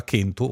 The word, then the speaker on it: kentu: tranne il sardo che la pronuncia ancora kentu, uno delle lingue della Sardegna, la pronuncia kentu, kelu, eccetera, eccetera.